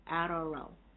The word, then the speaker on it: yarrow: Yero is spelled Y-A-R-O-W. Did I say it's spelled right? No. Y-A-R-R-O-W. Arro.